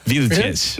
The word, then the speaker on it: Vilches: Oh, it's like wheelchairs, but with a V. – Vilches.